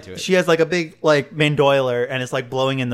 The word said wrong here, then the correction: bandolier: Bandolier? – Bandolier, thank you.